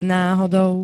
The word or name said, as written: nahodou